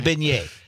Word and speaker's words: beignet: The beignet.